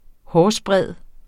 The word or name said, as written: hårsbredde